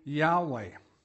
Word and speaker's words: Yahweh: That big L-O-R-D is Jehovah. That's Yahweh. That's the name even the Hebrews didn't like to pronounce because they didn't know how to pronounce it. We pronounce it Yahweh.